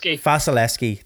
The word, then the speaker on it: Vasilevskiy: And obviously then their goaltender, Vasilevskiy. Why do I always give myself the worst names to pronounce? – Vasilevskiy, thank you.